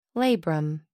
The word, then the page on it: labrum: American English